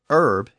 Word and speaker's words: herb: So the initial H was still silent in Middle English and Early Modern English. And when English speakers settled parts of the New World in the 17th and 18th centuries, they brought that pronunciation with them. That's why it's still herb in American English.